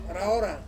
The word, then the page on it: raora: Ansus